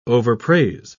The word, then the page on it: overpraise: British English